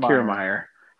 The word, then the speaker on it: Kiermaier: I mean, Kevin Kiermaier, or is that how you say it, Kiermaier from Fort Wayne? – Kiermaier.